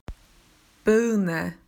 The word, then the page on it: pear: Australian English